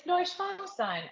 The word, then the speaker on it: Neuschwanstein: Did they tell you how to pronounce that word correctly? – Dachau? – No. The castle. – Neuschwanstein? – Neuschwanstein, okay. – It's Neuschwanstein. I was a German major.